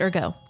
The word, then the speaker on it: ergot: It talked about a German researcher who concluded that dinosaurs ate ergot fungus. Perhaps it's pronounced ergo. I'm not actually sure. – Call it ergot. – It is usually ergo.